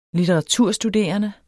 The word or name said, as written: litteraturstuderende